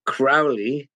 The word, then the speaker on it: Crowley: It's actually Crowley, not the same as Alistair, who pronounced his name Crowley. – Right. – Crowley is an English name. Mine is an Irish name with a totally different origin. Comes from Gaelic.